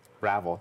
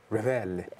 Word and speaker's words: Ravel: Ravel. Did I say it correctly? – Yeah, you did. – Okay. – A lot of people think that it's fancier. – Ravel.